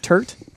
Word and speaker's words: turnt: Am I saying it correctly? Turnt? – Turnt? – Turnt. – Turnt. Thank you. – Turnt. – There's an N. – Just make sure to get the T in there.